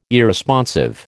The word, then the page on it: Irresponsive: American English